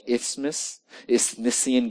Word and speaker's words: Isthmian: When does Paul pull out the, well, when they train for the Olympic games in the Isthmian, I'm not mispronouncing it, it's like Isthmian games, that's what the historians tell us.